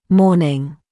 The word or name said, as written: morning